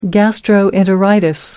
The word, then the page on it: gastroenteritis: American English